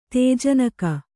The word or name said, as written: ತೇಜನಕ